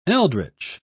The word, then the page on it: eldritch: British English